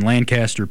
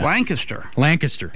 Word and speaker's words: Lancaster: So there's a Buchanan estate in Wheatland, which is his mansion in Lancaster, Pennsylvania. – Lancaster. – Yeah. Thank you. As a guy from Pennsylvania, I should know how to pronounce that.